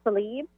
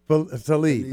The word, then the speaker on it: Tlaib: How do you pronounce the last name? – Rashida Tlaib. – Tlaib, right. Okay.